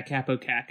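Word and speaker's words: Kaapo Kakko: Mentioned Capo Caco, as that's how you say it, right? – Yeah, Capo Caco.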